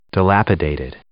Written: dilapidated

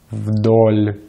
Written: вдоль

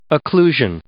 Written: occlusion

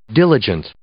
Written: diligent

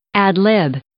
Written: ad-lib

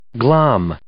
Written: glom

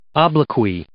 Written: obloquy

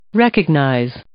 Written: recognize

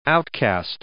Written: outcast

